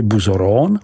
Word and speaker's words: Bouzeron: Naší první zastávkou musí být vinařská obec Bouzeron, vyslovuj Buzoron, která je společně s obcí Chassey-le-Camp, vyslovuj Chassey-le-Com, jedinou oficiální apelací pro přípravu vín z bílé odrůdy Aligoté.